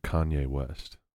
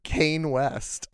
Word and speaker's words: Kanye West: How do they pronounce it? He calls him Kane West.